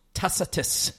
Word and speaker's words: Tacitus: And how do we pronounce that? The number 8 horse? – Tacitus.